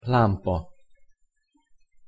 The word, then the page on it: lampa: Hungarian